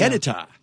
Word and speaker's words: HEDTA: Jennifer Brennan just texted me, "Hey, Mike, the Iron Active is actually listed as HEDTA, H-E-D-T-A.